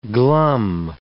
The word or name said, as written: glum